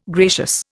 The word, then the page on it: gracious: Indian